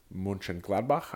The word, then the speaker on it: Mönchengladbach: Mönchengladbach, I finally learned how to say it.